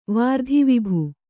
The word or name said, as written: ವಾರ್ಧಿವಿಭು